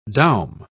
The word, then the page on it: doum: British English